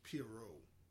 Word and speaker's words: Pierrot: It's pronounced Pierrot. I thought it was Pierrot, but it's Pierrot is how I'm looking at it being pronounced here.